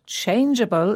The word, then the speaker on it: changeable: Yes, there are quite a lot of words with the letters G E in them, and the E shows that the G is pronounced softly as J, changeable.